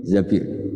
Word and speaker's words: Zabir: Di di hadis ini bukan bacanya bukan Zubair tapi Zabir.